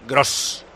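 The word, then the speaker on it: Groß: Que se pronuncia S, porque va a entrar Pascal Grob, que se pronuncia Pascal Gross.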